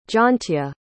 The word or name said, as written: jaantje